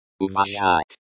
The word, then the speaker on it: уважать: Uvashat. This verb means, to respect. The pronunciation is close to, uvashat.